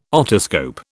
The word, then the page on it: otoscope: British English